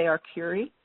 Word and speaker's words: Arcuri: Do you say Arcurie? Is that the correct pronunciation? – Yes.